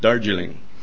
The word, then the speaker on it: Darjeeling: Did I say that right? Darjeeling? Darjeeling. Okay, and that's in the state of West Bengal, situated between Bangladesh and Nepal, way up in the north part there.